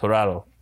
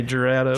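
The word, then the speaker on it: Jurado: I believe the dude has like Hispanic family, and it's spelled, if that's a Hispanic Hirado. Name, it's Hirado. – Hirado. I was doing it the American way, Gerardo.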